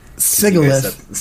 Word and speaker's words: Sigilyph: Because I'm sure there's probably a lot of Sigilyphs because a lot of people say Sigil wrong.